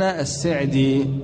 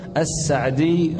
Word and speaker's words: السعدي: وقد ينطقون السين مكسورة فيقولون السعدي، والمشهور والأشهر فتح السين نسبة إلى سعد، العلامة عبد الرحمن بن ناصر السعدي رحمه الله.